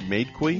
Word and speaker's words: Madekwe: Madekwe, M-A-D-E-K-W-E. Am I saying it right? Madekwe? – Madekwe.